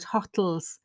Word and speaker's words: hotel: I didn't know what he was talking about, hotels. And we worked out he was talking about hotels because it is an unusual word stress on the second stress.